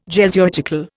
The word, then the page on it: Jesuitical: Indian